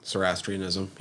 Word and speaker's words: Zoroastrianism: God, how do you pronounce that religion? Zoroastrianism? Mm-hmm. – Zoroastrianism, yeah.